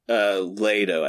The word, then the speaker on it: Leto: Leto, I think it's pronounced.